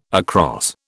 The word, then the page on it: across: British English